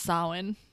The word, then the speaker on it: Samhain: Well, what I Googled said it was Samhain. – Same.